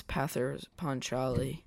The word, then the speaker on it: Pather Panchali: Now is going to take us into his review of Pathos Pancheros. I believe I'm saying that right. – I think it's Pather Panchali.